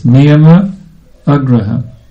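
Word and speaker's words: niyamāgraha: In Sanskrit grammar, if there's an A on the end of a word, niyama, and there's an A on the beginning of the next word, then those are two short A's, niyama, agraha. – Санскритски език, ако една дума завършва на А, и другата, следващата започва също с А, и те са две кратки гласни, those two short A's merge together as one long A, Niyama Agraha.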